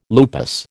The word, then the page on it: lupus: American English